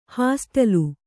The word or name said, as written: ಹಾಸ್ಟೆಲು